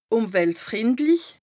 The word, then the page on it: ùmweltfrìndlich: Bas Rhin